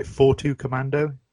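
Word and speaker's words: 42 Commando: 4-2 Commando? – Yeah, that's the one, yeah.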